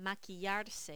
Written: maquillarse

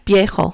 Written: viejo

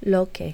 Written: lo que

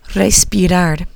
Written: respirar

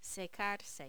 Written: secarse

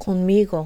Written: conmigo

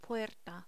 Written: puerta

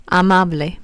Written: amable

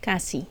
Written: casi

